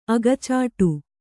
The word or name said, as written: ಅಗಚಾಟು